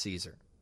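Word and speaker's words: Szczur: That you would want something that would highlight the fact that his last name is pronounced Caesar.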